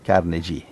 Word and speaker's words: Carnegie: Come si dice? – Carnegie.